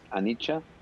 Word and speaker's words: Anicca: It's spelled A-N-I-C-C-A, but it's pronounced Anicha, but it's spelled with double C and an A, so it's spelled like an Annika.